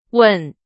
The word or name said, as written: 稳